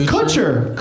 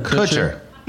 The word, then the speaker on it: Kutcher: You're going out of your way to not pronounce his name right. – How do you say it? – I just said it's Kutcher. – Kutcher.